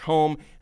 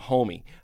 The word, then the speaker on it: Homme: Uh, if you go to Europe, apparently it's pronounced Josh Home, and some people like just call him Homie.